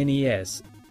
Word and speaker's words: NES: He says, I missed a lot of the NES, pronounced N-E-S. So there.